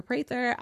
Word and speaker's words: Prather: And then my favorite platform that you can find me on is in YouTube, and that's Angelica Prather.